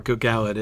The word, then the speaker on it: Gil-galad: No, Gil-galad is how it's supposed to be pronounced.